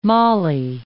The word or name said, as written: molly